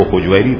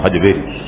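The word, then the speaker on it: ہجویری: حجویر اور حجویر دونوں طرح اس کا تلفظ کہا گیا ہے، حقیقت یا اللہ بہتر جانے، بعض اس کو حجویری پڑھتے ہیں بعض حجویری.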